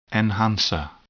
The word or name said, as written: enhancer